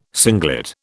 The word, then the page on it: singlet: British English